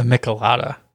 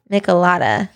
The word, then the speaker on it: Michelada: Well, you added an N in there, a Michelada. – Michelada. – You said a Michelada.